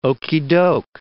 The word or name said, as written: okeydoke